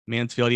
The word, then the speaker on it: Mansfield: Like James Mansfield, is it Mansfield? – Mansfield, yeah.